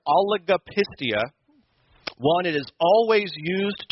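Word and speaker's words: Oligopistia (ὀλιγοπιστία): I think it's 6 syllables. I'm going to try it once. I want you to say it after me. Oligopistia.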